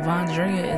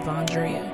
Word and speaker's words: Vondrea: Vondrea is my name. I want you to know my name. My name is Vondrea. Not Vondrea, not Vondera. V-O-N-D-R-E-A.